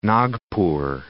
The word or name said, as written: Nagpur